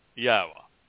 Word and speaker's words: Yahowah: If you can say Torah, you can pronounce Yahowah. If you know how to pronounce the Hebrew verb that means to be, to exist, Hayah, you can pronounce Yahowah.